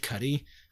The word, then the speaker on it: Cudi: it's like Kid Cudi. Where, is that how you say his name? Kid, mm-hmm, Cudi? – Yeah.